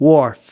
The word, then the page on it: wharf: American English